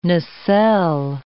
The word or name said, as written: nacelle